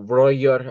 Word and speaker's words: Roger: He, the manager, head coach, of course, is Roger or Royer, I believe is the German pronunciation.